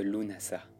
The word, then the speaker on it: Lughnasadh: Lunasa. Lunasa, écrit parfois Lugnasad, qui se prononce l'unasa ou lamas, fête du pain en vieil anglais.